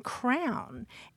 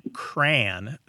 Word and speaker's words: crayon: And then there, are people, then there are people who say cran, sounds like rhymes with the first part of cranberry, mm-hmm. – rhymes with man.